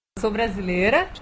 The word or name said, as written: sou brasileira